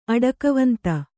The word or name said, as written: ಅಡಕವಂತ